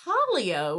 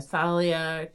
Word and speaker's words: Talia: Yes, I like the name Talia, which is kind of like Talia, or you could pronounce it with an H, the Thalia, Talia, Talia. – Mm-hmm. – I like Talia, T-A-L-I-A, and shout out to my former niece-in-law, Talia Shapiro, who incidentally is marrying a friend of your daughter, Miranda.